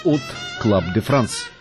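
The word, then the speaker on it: Hot Club de France: В 1932 году Юг основал от Club de France. Первое H французы не произносят, отсюда и фонетическое от Club de France.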